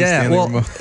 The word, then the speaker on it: Stanley Rameau: I usually go Stan the Man, but we could do Stanley Rameau.